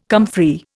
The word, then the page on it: comfrey: Indian